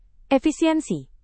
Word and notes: efisiensi: indonesian

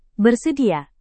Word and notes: bersedia: indonesian

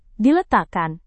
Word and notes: diletakkan: Indonesian

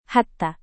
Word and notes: hatta: Indonesian

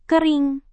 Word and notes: kering: indonesian

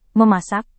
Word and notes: memasak: Indonesian

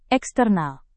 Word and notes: eksternal: indonesian

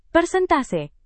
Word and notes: persentase: Indonesian

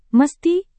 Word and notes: mesti: Indonesian

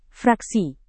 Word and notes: fraksi: indonesian

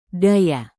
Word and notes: daya: indonesian

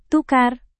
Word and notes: tukar: indonesian

